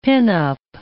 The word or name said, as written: pinup